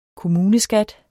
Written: kommuneskat

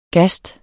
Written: gasts

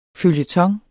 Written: føljeton

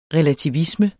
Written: relativisme

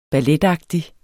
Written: balletagtig

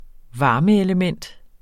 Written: varmeelementer

